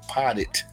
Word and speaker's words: potted: He said potty, not potty meat, nigga, potted. Potted meat. Yes, potted.